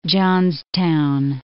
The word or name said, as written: Johnstown